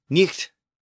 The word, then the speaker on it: night: So earlier, I mentioned the word night as in nighttime, and I noted that it was originally pronounced nicht, and it was spelled N I H T in Old English with the H representing that fricative sound.